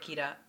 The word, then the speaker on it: Ciara: Porque estaba pensando en cómo introducirme, porque, claro, mi nombre se escribe C-I-A-R-A. a r a se pronuncia Kira, no me llaméis Ciara, por favor.